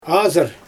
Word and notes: azyr: Xyzyl

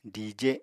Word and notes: dije: apatani

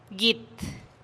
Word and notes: git: TdVZ

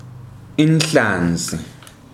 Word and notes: inhlanzi: ndebele

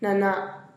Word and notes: nanaa: TdVZ

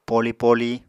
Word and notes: poli-poli: Apatani

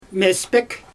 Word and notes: mespek: Xyzyl

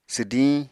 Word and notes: swdiñ: apatani

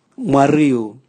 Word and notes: mwariu: Raga

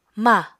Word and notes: mah: apatani

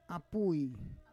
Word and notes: apui: Roon

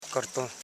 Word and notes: karton: kar.ton; Koro

